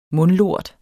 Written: mundlort

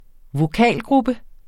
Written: vokalgruppe

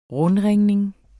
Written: rundringning

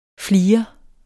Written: FLIREN

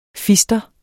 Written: fister

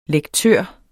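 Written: LEKTØRER

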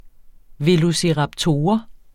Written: velociraptor

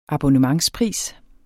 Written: abonnementspris

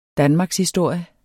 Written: DANMARKSHISTORIER

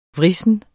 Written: vrissen

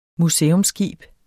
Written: museumsskib

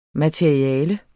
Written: materiale

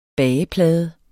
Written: bageplade

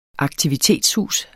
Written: aktivitetshus

